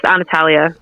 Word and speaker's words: Antalya: Is it Antalya? Is that how it's said? – Yes, Antalya.